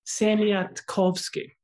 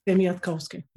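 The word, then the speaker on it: Siemiatkowski: jag lyssnade på Sebastian, eh, vars efternamn jag, jag, jag aldrig lär mig att uttala. Kan du det? – Semiatkowski. – Ja, så är det. Va? Just det. – Semiatkowski.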